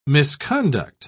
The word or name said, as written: misconduct